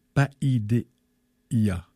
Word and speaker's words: Paideia: Euh, l'éducation en vue des affaires communes, c'est le PAIDEIA. P-A-I-D-E-I-A. E Donc, ça se prononce comme le Paideia.